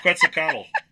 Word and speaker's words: Quetzalcoatl: It's Quetzalcoatl.